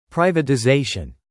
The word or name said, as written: privatization